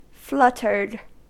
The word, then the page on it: fluttered: American English